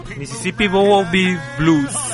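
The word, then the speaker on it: Mississippi Bo Weavil Blues: A ver, señor, ayúdeme a pronunciar aquí porque me siento el bestereado. – Mississippi Bowieville Blues.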